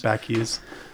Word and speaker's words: Scott Backes: But you and Mark and Scott Backes, is that how you pronounce his name? – Backes.